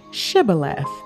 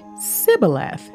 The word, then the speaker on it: Shibboleth: If he said no, then they would say to him, "Just say Shibboleth." But he said Sibboleth, for he was not prepared to pronounce it correctly.